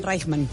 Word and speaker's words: Reichmann: Y así encontramos a Jessica Rachman. ¿Lo dije bien? Reichmann. – Reichmann. – Jessica Reichmann. O sea, eso es que es alemán.